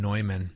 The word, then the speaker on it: Neumann: It's John Neumann. – See, wow. I know. – I looked it up. Rhymes with Toyman. – I'm telling you. So Neumann, I've never heard of him. I think of Newman.